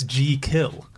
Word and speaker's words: Jekyll: The first one is, we're all saying it wrong. It's Jekyll. – Jekyll. – Jekyll. If you were pronouncing it, it's J-E-E, and then kill.